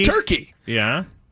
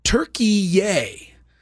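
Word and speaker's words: Türkiye: Turkey is also known as Turkey-ye. And I might be pronouncing it wrong, but it's spelled T-U-R-K-I-Y-E. And that's the Turkish way to say Turkey. I guess it's Turkey-ye or something like that. Anyway, I'm bringing this up.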